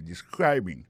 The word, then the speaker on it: describing: Alors, describing non c'est participe présent verbe to describe ça nous carré là ça nous constater là nous ca constater que il a prononcé mot de describing accent tombé sous deuxième syllabe là